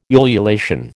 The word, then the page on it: ululation: American English